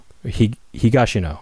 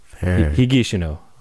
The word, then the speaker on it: Higashino: Higashino. I'm trying to, you mentioned my Japanese pronunciation last show. I'm trying to do the non-emphasis right. – syllables thing. – Right. – So, Miki Higashino. Higashino.